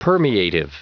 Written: permeative

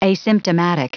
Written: asymptomatic

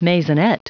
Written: maisonette

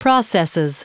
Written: processes

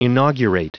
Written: inaugurate

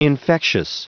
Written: infectious

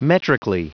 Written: metrically

